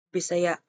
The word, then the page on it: Bisaya: Cebuano